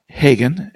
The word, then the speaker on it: Hagen: Okay, it's my first name separated from my last name by a dot, so it's Peter, P-E-T-E-R, dot Hagen, and that's spelled H-A-G-E-N, at Stockton, Stockton.edu.